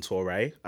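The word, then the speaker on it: Touray: On Instagram, I am Jason Touré. Surname is spelled T-O-U-R-A-Y. J-A-S-O-N-T-O-U-R-A-Y.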